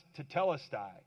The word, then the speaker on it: tetelestai: It's a Greek word, and I don't usually get into word studies too much, but it's pronounced tetelestai.